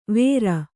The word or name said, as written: ವೇರ್